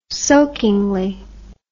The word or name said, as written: soakingly